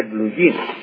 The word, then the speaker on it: blue jean: Entonces ya, por ejemplo, usted encuentra en el diccionario blue jean, así con Y, porque esa es la pronunciación del inglés de la palabra blue hands. Entonces se dice blue jean.